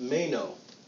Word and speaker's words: meno: It's pronounced meno. Meno. And meno is the Greek verb.